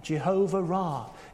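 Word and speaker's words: Jehovah Ra'ah: It's one name, Jehovah Ra. It's good that it's pronounced like that, isn't it? Because Ra rhymes with Ba.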